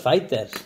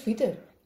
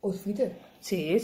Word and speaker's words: Outfitters: Que no es fighters. – No es fighters. – No es, fighters. Es, que es fitter. – Outfitter. Sí, sí que lo he dicho bien entonces.